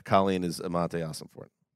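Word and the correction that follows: Monti Ossenfort: Is that how you pronounce it? Mossy? – Monty Ossenfort. – Monty.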